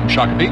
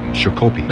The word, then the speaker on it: Shakopee: Shakopee. – Mm-hmm. It's Shakopee. It's a Native American name, sure. – And it's very important that you get it right. – It is. – He goes, Shakopee, the phones ring.